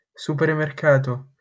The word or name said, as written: supermercato